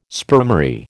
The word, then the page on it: spermary: American English